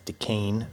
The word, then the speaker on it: Duquesne: It's pronounced Duquesne, but go on.